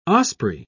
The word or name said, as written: osprey